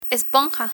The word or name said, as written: esponja